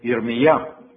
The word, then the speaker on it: Yirmiyah: But Yah in Hebrew, when it comes in the beginning, is never Yah. It is changed to Yeh. But if it comes as a second syllable or the end of the word, then you can say Yah, like Jeremiah, or in Hebrew, Yirmiyah, or Hezekiah, or in Hebrew, Yechezkiah. You see, Yah will come at the end, but when Yah comes in the beginning, it changes to Yeh.